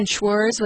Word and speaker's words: Schwarz: I just found out that the conductor of the Seattle Symphony, his name is the same as mine, Schwarz without the T, S-C-H-W-A-R-Z, Schwarz. It rhymes with s'mores, remember?